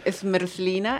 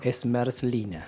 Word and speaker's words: zmrzlina: El nombre Smerslina es helado. – Smerslina. – Sí, Smerslina. Se escribe como S-M-R, o sea, perdón, Z-M-R-Z-L-I-N-A. – Sí, sí.